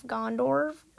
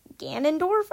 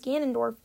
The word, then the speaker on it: Ganondorf: If I was going to build a game, I would probably say it's probably going to be, like, finally finishing off Gondorf, or Ganondorf, I think that's how you pronounce it.